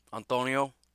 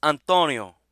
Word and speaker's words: Antonio: I thought you were Italian. It's Antonio. Antonio. So I get that he's an American guy with an American pronunciation of a not very American name. And I should probably call him Antonio, because it is an A, after all, and not an O.